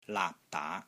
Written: LAAP3 DAA2

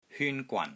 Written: HYUN1 GWAN3

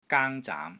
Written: GAANG1 ZAAM2

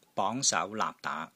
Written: BONG2 SAU2 LAAP3 DAA2

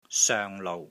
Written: SOENG6 LOU6

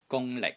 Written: GUNG1 LIK6

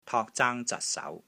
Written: TOK3 ZAANG1 ZAT6 SAU2

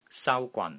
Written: SAU1 GWAN3

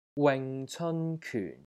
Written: WING6 CEON1 KYUN4

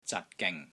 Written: ZAT6 GING3

